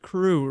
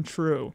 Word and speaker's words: CHRU: WXYC would like you to know about the Carolina Harm Reduction Union, known as CHRU, but it is pronounced CREW rather than TRUE.